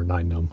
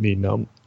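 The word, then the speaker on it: Nien Nunb: It's pronounced Neenum or Nine Num. I always did it. Neenum.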